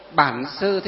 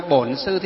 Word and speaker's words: Bản Sư: Nam Mô Bản Sư Thích Ca Mâu Ni Phật, là tiếng miền Bắc. Miền Nam đọc là Nam Mô Bổn Sư Thích Ca Mâu Ni Phật, là tiếng miền Nam.